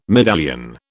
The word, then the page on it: medallion: British English